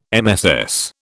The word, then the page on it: MSS: British English